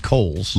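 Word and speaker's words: Cowele: It looks like cowls, but it's pronounced Coles.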